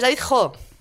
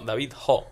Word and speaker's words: David Ho: O sea, es David Ho. – No, David Ho, sí.